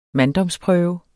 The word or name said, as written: manddomsprøve